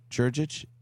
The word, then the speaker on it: Djurdjic: It's Marko, M-A-R-K-O dot Djurdjic, D-J-U-R-D-J-I-C.